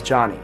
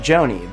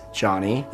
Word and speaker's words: Jony: For example, my name is Johnny, spelled J-O-N-Y. And it's normal for someone to pronounce my name like Joni.